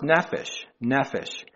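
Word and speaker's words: nephesh (נפש): Well, that Hebrew word for soul, that's again, if you look at Strong's Concordance, it's H5315, and it's pronounced nephesh.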